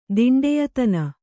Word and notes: ದಿಂಡೆಯತನ: Kannada

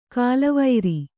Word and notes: ಕಾಲವೈರಿ: kālavairi

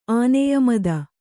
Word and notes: ಆನೆಯಮದ: āneya mada

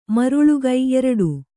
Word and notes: ಮರುಳಿಕ್ಕು: kannada

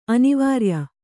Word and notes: ಅನಾವ್ರಯ: anāvraya; Kannada

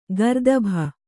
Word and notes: ಗರ್ದಭೆ: gardabhe

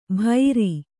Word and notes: ಭೈರಿ: bhairi

